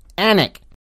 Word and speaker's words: Alnwick: Towcester is pronounced toaster, and Alnwick is pronounced Annick.